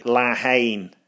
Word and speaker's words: La Haine: Or to pronounce it properly, La Haine.